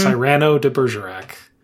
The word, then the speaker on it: Cyrano de Bergerac: She pronounces it in a way that tells me that she has read it and not heard it, because she said mm-hmm. Cyrano de Bergerac. – Yep. – And I was like, hey, good for you. – Yep. Ken is so tactful as he gives the correct pronunciation.